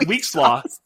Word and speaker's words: Huixtla: No, it's pronounced Wixla. Wixla, but it starts with an H. It's Huixtla.